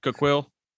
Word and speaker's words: Coquille: Kahili? – Yeah, I forgot how to pronounce it. It's 15 Coquille, minutes. – Coquille? – Yeah, it's 15 minutes inland and it's 85.